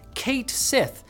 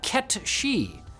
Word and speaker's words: Cait Sith: Square Enix has sided with fans who pronounced it phonetically in English as Kate Sith, disappointing others who interpreted it as Ket She, based on the name's origin in Gaelic mythology as a black cat spirit that steals the souls of the dead.